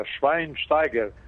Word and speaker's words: Schweinsteiger: Schweinsteiger, Bastian Schweinsteiger, abreviação hum.